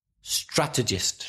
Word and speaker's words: strategist: strategist. – Strategist. It is quite difficult to pronounce. – Stressing the first syllable, right? Yeah. – So a strategist. Also three syllables. Stress the first syllable.